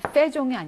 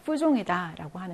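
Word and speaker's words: faisons: 근데 이제 발음이 이제 페종이 아니라 푸종이다라고 하는 거.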